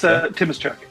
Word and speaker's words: Tymoschuk: That's pretty good, actually. It's Tymoschuk, but you're like 98% of the way there.